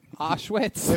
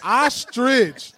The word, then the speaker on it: ostrich: Auschwitz. – If I didn't grow up saying that shit, I can't really say it like that. Right? – Who the fuck eats Auschwitz? – Ostrich.